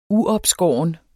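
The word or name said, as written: uopskåret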